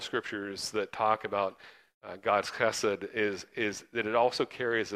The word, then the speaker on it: chesed: And that's God's chesed. You got to say it like you're trying to spit at someone. It's from the back of your throat.